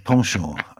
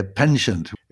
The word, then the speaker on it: penchant: Well, first of all, so I'd like to congratulate you on your pronunciation of penchant. I was expecting to hear penchant. It's a good English word, so nothing wrong with that.